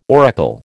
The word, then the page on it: oracle: American English